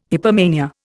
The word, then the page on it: hypomania: Indian